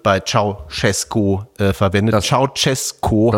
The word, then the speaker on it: Ceaușescu: Meine Lieben, der erschossene rumänische Diktator wird ausgesprochen wie Ceaușescu. Der ausgeschlossene Präsidentschaftskandidat wird ausgesprochen Georgescu. Das J jeweils weich, wie Georg im Englischen.